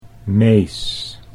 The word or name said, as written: Mees